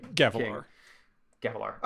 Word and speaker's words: Gavilar: I feel like we're going to get Gavilar. Or Gallivar, did I spell that wrong? The king. – Gavilar. – Oh, I did get it right.